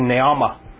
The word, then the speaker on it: Naamah: Now, it mentions here the sister of Tubal-Cain was Naamah, close to Naomi, almost, in pronunciation, Naamah.